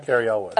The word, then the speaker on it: Elwes: Cary Elwes.